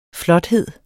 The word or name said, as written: flothed